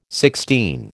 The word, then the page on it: sixteen: American English